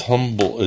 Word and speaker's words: Humble: And it says, no, it's pronounced Humble. It's spelled Humble, Texas, but it's pronounced like the H is silent.